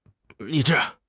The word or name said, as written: 理智